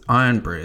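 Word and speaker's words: Irn-Bru: It's Iron Brew. It's spelled like Earn Brew.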